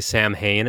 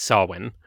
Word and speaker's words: Samhain: A lot of people say Samhain, and it's to the point that that's almost acceptable now. But traditionally, it's Samhain.